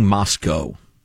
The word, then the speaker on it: Moscow: I was going to get used to saying Moscow, because Moscow is the proper pronunciation.